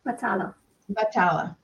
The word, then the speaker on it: Batalla: Batalla.